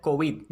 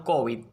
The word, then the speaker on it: COVID: Así, lo normal es pronunciar COVID, no COVID, como mismo decimos OTAN y no OTAN.